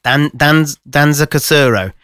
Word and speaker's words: Danza Kuduro: Danza Kuduro. – Danza Kuduro. – Because it's spelled D-A-N-C-Z-A. – Danza Kuduro. – Yeah, and then K-U-D-U-R-O.